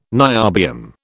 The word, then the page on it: niobium: British English